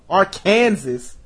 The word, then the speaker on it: Arkansas: west to fucking Arkansas, Arkansas, as it should be pronounced.